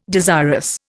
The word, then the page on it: desirous: Indian